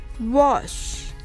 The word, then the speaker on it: wash: wash は w を発音したら sh の時は息とともに唇を尖らせて発音してくださいそれでは練習してみましょうまずはゆっくり wash